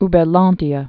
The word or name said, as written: Uberlandia